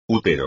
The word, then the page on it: útero: espagnol